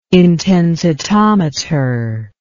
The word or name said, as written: intensitometer